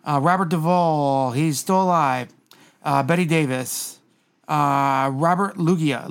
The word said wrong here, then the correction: Loggia: Loggia, sorry. – I only know after a Pokemon. – The only reason I know his name is Robert Loggia, pronounced that way, because there was a commercial for...